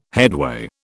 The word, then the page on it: headway: British English